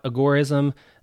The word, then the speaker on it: agorism: And let's start off with pronunciation, because usually when I hear someone talking about agorism, I usually hear it pronounced agorism, and agorism kind of flows a little better, I think. But when the term was coined by Samuel Edward Konkin III, he termed it agorism, and that's how he pronounced it. And when you go back to the root word that agorism is based off of, that is agora, the pronunciation would signify that agora would be agorism.